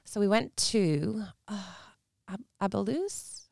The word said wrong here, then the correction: Andalus: I'm not sure if I'm pronouncing that right. – Andalus? – Andalus, yeah.